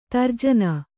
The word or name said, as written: ತರ್ಜನೆ